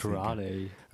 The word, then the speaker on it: Raleigh: Das heißt Raleigh.